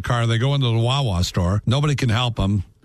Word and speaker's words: Wawa: A couple of women got locked out of their car outside a, is it pronounced Wawa store? – Wawa's, yes.